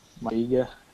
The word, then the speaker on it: Maiga: Think Gomis will end up at Stoke City like Eric Peters and or at West Ham like Modibo Maiga, however you say his name. Maiga, is that right? – Yeah.